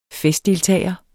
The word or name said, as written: festdeltager